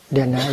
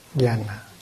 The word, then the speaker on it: Dhyana: The word Zen is a Japanese pronunciation of dhyana. Dhyana is a Sanskrit term, mean it is for meditation. The Chinese pronounce this as Chan, Chan Buddhism, the Japanese Zen, and the Vietnamese Thiền. The Sanskrit, Dhyana, Dhyana.